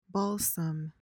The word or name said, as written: balsam